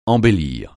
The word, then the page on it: embellir: francais